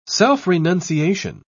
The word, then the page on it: self-renunciation: British English